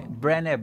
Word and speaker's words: Brené: Tá Bre Brené Brown.